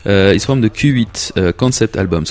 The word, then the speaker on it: Qhuit: So Q8, Q-H-U-I-T, is a brand of streetwear. And if you pronounce it Q8, it means when you get smashed, when you get trashed, when you get pissed in the British sense, when you just drink way too much.